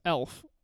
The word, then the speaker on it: elf: It's elf. – Uh-huh. – E-L-F.